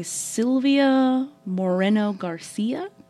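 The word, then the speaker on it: Silvia Moreno-Garcia: This month we have Mexican Gothic by Silvia Moreno Garcia. Am I saying that right? – That's the way I pronounce it. – Yeah, as far as we know, we got that right.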